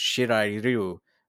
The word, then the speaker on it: Shirai Ryu: It's technically supposed to be pronounced Shirai Ryu.